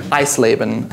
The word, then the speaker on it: Eisleben: Eisleben.